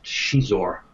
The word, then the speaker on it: Xizor: You've got Darth Vader, you got and this, by the way, this character's name is pronounced Xizor. It's it's X-I-Z-O-R. That wasn't my name. I think Lucy Wilson, the vice president of the publishing section, came up with that. Because I think she had knew somebody who was Portuguese, and that's a Portuguese pronunciation.